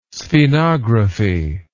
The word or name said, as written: sphenography